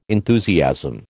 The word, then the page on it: enthusiasm: American English